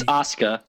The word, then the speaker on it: Asuka: It's Asuka. – It is Asuka. I did pronounce it wrong, to be fair. – Oh. – It is Asuka. I pronounced it wrong.